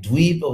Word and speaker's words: Dvipa: Actually, the word Dvipa, not to be confused with the word Dvipa, with the long I. Dvipa with the long I means island, like Navadvipa. Nine islands. So this is Dvipa with a short I, which means twice, two times, like Dvija, twice born.